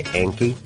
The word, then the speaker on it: Anki: Now, a lot of Americans might say Anki. – Like it rhymes with Yankee. – Yeah, I guess so. It's like on key or like almost like you're saying donkey. – Yeah, it's spelled A-N-K-I.